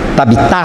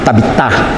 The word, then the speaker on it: Tabitá: Tabitá, se for no aramaico isso. Geralmente o aramaico e hebraico, a tônica é na letra final, na sílaba final, né? Tabitá.